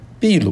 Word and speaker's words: 秘鲁: 那个第一个呢，是在秘鲁开的会。这个秘鲁这个国家，他们自己的西班牙文读 Peru 啊，我们呢读成秘鲁，有些人读成秘鲁。其实这个中文字呢是秘鲁，但是这个字应该读秘，就必然的秘，因为它那个原来的名字读 Peru 啊。这个秘鲁的最大的城市叫利马啊，首都利马，在那里呢开了一次会，就是这个 APEC 峰会。